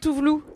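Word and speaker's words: Tove Lo: Ah oui, je confonds avec Tuvlu, qui s'écrit Tovlo.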